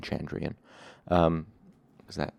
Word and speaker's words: Chandrian: Now that's um, that's sort of a weird word, but it's it's spelled C H A N D R I A N, Chandrian. So that's how I'm going to pronounce it. It might be Chandrian. Um, or, or possibly Candrian, but I'm going to say Chandrian.